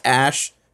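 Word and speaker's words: Æ: When someone asked the baby's name, Musk replied, and this is over Twitter, X Ash, which is the A and the E put together. It's pronounced Ash.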